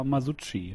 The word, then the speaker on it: Masucci: Hagen Kutscher, Oliver Masucci.